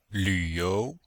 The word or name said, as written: lv3you2